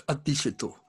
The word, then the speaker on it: Adishito: So you also mentioned, if I'm pronouncing it correctly, Dishitu? – Very, very close. There's a little on the front. It's Adishito.